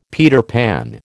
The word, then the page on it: Peter Pan: American English